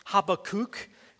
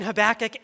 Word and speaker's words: Habakkuk: For the Old Testament nerds here, I know that it's pronounced Habakkuk. And if you're like thinking, this guy is so ignorant, he's mispronouncing Habakkuk, that's for free for you guys. For the rest of us, I'll keep saying Habakkuk, and you're okay if you keep saying it that way too.